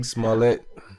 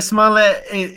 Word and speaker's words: Smollett: Smollett. – Yeah, Smollett.